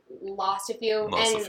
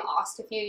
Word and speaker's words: Ostafew: So it's Maria_Ostafew, exactly like the word Ostafew, and that's how you pronounce my last name, Ostafew.